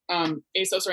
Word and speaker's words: ASOS: Am I saying that right? Because I always think. – I think right? – I think it's ASOS. Yeah. – But I'm actually wearing ASOS right now.